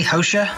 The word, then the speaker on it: Xhosa: Well, lady from the traditional people, I don't know how to pronounce it, I suppose you'd say X-H-O-S-A, Hosha? – Yeah. – People of South Africa. Mm-hmm. Apologies to all you kosher people. Not kosher, Hosha people.